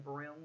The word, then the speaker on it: Broome: Okay, so in 2009, a woman named Fiona Broome I think that's how you pronounce her last name. It's spelled like Broom with an E on the end. Yeah, yeah, yeah. Broom.